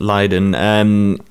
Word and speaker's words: Lydon: John Lydon is how you pronounce it. Lydon. So he said the series was very disrespectful.